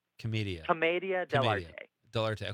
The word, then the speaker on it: Commedia dell'arte: Commedia dell'arte.